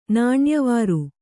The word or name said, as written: ನಾಣ್ಯವಾರು